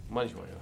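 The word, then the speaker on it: Mannish Boy: Manish Boy.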